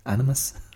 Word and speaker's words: animus: I don't think he had any great personal animus. Is that how you pronounce that word? Animus? Animus? Animus. Animus will do.